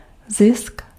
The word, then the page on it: profit: /ˈprɒ.fət/